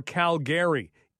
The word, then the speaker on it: Calgary: By the way, on a note regarding pronouncing names properly, and somebody had said Calgary or Calgary. It is Calgary.